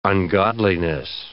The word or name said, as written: ungodliness